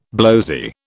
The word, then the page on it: blowzy: British English